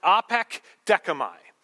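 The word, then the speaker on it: apekdekomai: It's pronounced apekdekamai.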